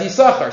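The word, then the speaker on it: Yisachar (יששכר): Most minhagim, most shuls in the world, lane it, the word Yisachar, as Yisachar. Silent second sin, start to finish. Yisachar is always pronounced Yisachar.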